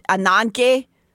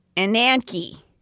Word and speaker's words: Ananke: It's Greek, and so with a Greek accent, it seems like you say it Anangye, but it's spelled like Ananke. – Ananke.